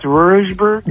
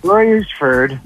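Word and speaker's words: Royersford: How do you pronounce Roersburg? – Roersford.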